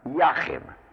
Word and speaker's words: Jachin: This is also written in some texts as J-A-C-H-I-N. But since in Hebrew, J and Y and I are various forms of yod, they all are pronounced in the same way. Yachin.